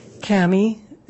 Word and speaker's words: Cammy: Cammy, C-A-M-M-Y, Wilmore, W-I-L-L-M-O-R-E.